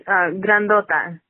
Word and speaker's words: Grandota: It's Grandota. – Okay, well, you know what? You need to just get that out there. – If you can roll your R's, if you can pronounce it.